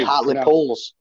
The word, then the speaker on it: Hartlepool: They're called Hartlepools. It's Hartlepools United. That's what they used to be called. – You don't pronounce the H, do you? – Hartlepool, like that.